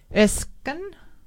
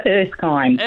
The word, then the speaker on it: Erskine: How do you say that? Erskine. Sure. Good morning. It's Erskine.